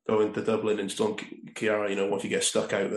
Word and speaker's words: Ciara: and it was Storm Ciara, or Ciara, as they pronounce it over there, at the time.